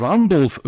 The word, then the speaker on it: Randolph: Ben Rudolph diye daha önce telaffuz etmiştim diye hatırlıyorum. Yalnız telaffuz etmişim. Dwayne John Randolph ödülü aldı.